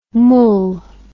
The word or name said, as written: moule